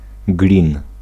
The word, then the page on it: aluminum: American English; /ə.ˈlu.mɪ̆.ˌnə̆m/